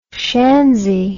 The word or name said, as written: schanzi